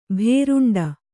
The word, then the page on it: ಭೂರುಂಡಿ: bhūruṇḍi